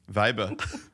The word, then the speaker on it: Weibe: And when we saw your last name, which is spelled Weibe, of course, we pronounced it Vibe. – Of course. – Vibe.